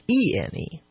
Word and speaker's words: graphene: And graphene is spelled not graphine as in graphite. It's graphene.